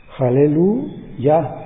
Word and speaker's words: aleluya: es la palabra aleluya. Una palabra hebrea que, según esto, la manera correcta de pronunciarla sería Haleluya. O sea, ya es una contracción de Yahvé y significa alaben a Yahvé, o glorifiquen a Yahvé, o agradezcanle a Yahvé.